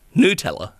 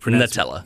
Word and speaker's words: Nutella: It is actually pronounced Nutella. N-U, and then Tella. Nutella. – As opposed to the standard Australian of Nutella.